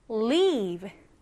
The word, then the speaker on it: leave: 이번에는 leave. 방금 봤던 단어는 live, leave고요. 얘는 leave, leave. 좀 길어졌어요, 그쵸? 자, 이 단어 스펠링부터 체크해 보겠습니다. 같이 한번 써보면서 바로 외워요. leave, L-E-A-V-E.